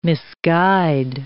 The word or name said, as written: misguide